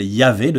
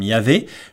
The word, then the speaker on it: Yahvé: Et là, je les ai prononcées en mettant les voyelles Yahvé, de sorte à ce que ça sonne comme Yahvé.